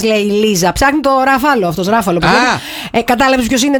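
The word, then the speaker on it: Ruffalo: Μετά Μαρκ Ράφαλο. Καλά το λέω αυτό. Μ' αρέσει αυτός ο ηθοποιός. Ράφαλο, προφέρεται.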